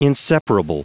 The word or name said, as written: inseparable